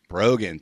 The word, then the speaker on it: Brogan: Um, Brogan. Thank you. Like bro and again.